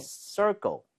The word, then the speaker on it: circle: 발음할 때 dark도 그렇지만 이 네. – circle 이거 쉽지 않아요. 왜냐하면 얘는 r도 있고 l도 섞여 있죠. – 예. – 혀가 뒤로 당겨졌다가 앞으로 금방 뻗어야 됩니다.